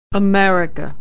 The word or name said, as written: America